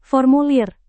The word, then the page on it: formulir: indonesian